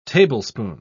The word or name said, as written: tablespoon